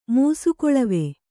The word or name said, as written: ಮೂಸುಕೊಳವೆ